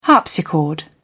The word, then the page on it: harpsichord: British English